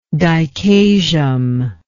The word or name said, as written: dichasial